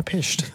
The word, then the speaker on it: Pysht: P-Y-S-H-T, Washington. Pisht. – Yeah, Pisht. That's pretty good.